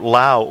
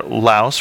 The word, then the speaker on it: Laos: This time we wanted to talk about Laos, or Laos, for those of us who haven't been there and don't know not to pronounce the S.